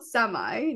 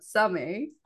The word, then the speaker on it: Samay: Yeah, it's called Samay, not Samay. Okay. Like you would pronounce it in English or Hindi.